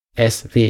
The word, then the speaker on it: ESWE: Also es sind in der Tat ja vier Buchstaben, die vier Buchstaben E, S, W, E. Und im Grunde ist es das Lautmalende, das Phonetische, wenn man es so nennen will, Aussprache der beiden Buchstaben S wie Siegfried und W wie Wilhelm.